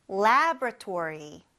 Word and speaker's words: laboratory: 그래서 스펠링을 보면은 lab, lab이고 그 다음에 o가 나와서 laboratory 하고 싶은데 labo라고 하지 말고 labra 그냥 바로 ra로 건너뛰어서 발음을 줄여서 lab, lab, lab이라고도 하니까 그것도 잊지 마세요.